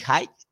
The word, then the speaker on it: Haig: Se escribe Matt, eh, M-A-T-T, y Haig... o algo así. Bueno, su apellido se escribe H-A-I-G.